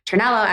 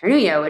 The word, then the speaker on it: Ternello: My last name is Ternello, as you know, and it has that double L. Mm-hmm. And so sometimes, you know, people have, you know, pronounced it Ternullo, which would suggest that I'm a Spanish speaker, maybe a Latino woman.